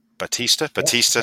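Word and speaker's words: Bautista: It's Dave Battistuta. I believe that's correct. – Batista, yeah. Sorry. I've turned him into an Argentinian footballer there.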